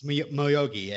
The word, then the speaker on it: Moyogi: It's literally spelled like Miyagi with, but instead of I and A in the vowel, it's O. – Two O's. – Two O's. Moyoji. – Moyogi. – No, I actually put that one into a pronunciation guide, oh, and it says that G at the end. – What? – It's pronounced just like G. – G-I? I don't know. That G-I is almost, I've never heard it not be gi.